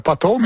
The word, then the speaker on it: potholes: Bravo, potholes, mais il faut bien prononcer le P.